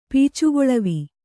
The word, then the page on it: ಪೀಚುಗೊಳವಿ: pīcugoḷavi